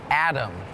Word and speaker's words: atom: Atom is tricky because it sounds like there's a D in it Atom but it's actually A T O M but it's still pronounced Atom